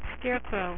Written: scarecrow

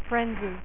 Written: frenzy

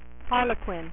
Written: Harley Quinn

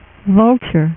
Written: Vulture

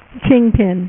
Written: kingpin

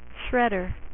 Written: Shredder

